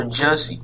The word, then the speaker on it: Jussie: Listen, it's spelled J-U-S-S-I-E. I don't know if it's Jesse or Jussie. – Jussie. – Right, or Juicy.